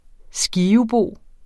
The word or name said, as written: skivebo